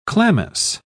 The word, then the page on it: chlamys: British English